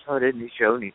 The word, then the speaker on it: Haudenosaunee: is it Haudenosaunee? Is that it? – The Onondaga pronunciation, as well as I can do it, is Haudenosaunee. But I have to tell you, it took me about 2 years for that to come trippingly to my tongue.